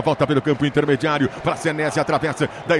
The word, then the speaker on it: Senesi: Yuri Queiroga, você que é o nosso Yuripédia, a gente vai de Marco Senesi ou Senesi, a pronúncia correta? – Senesi, Senesi. Senesi, então o narrador estava falando certinho, né? – E o Anderson também.